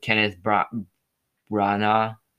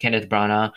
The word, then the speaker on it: Kenneth Branagh: The cast of the first film returned for the sequel with the additions of Kenneth Branagh. That's how you pronounce it. Kenneth Branagh, Jason Isaacs, and Gemma Jones, among the others.